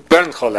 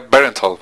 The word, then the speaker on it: Bernthal: John Bernthal, así es como su pronuncia, me parece. – Ah, ¿sí? Yo le decía Bernthal, pero no es Bernthal. – Parece que es más contraído el sonido.